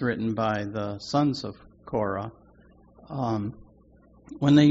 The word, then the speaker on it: Korah: Psalm 84 is written by the sons of Korin. Am I pronouncing that right, honey? Korin? You can look at it in your Bible. Korah. Yeah, Korah. Okay, there we go.